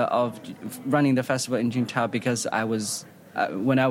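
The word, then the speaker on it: Qingdao: It's called Qingtao.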